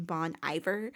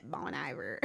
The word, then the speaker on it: Bon Iver: I just recently learned that you do not pronounce his name Bon Iver. It's Bon Iver. So anyways, that just sounds so so yeah, I've just been pronouncing it Bon Iver, like I'm from Arkansas, you know. But it is Bon Iver.